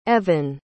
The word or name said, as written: Evynne